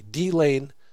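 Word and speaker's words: Dilane: That's spelled D-I-L-A-N-E, D-Lane, near Waynesboro.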